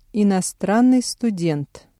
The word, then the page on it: иностранный студент: Russian